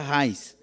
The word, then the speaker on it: Reis: Eu sempre digo Reis, que escreve Reis, mas se pronuncia Reis.